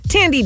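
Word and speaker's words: Thandie: Sally Field, Ethan Hawke, Tandy Newton. Sorry for 4 hours calling you Sandy.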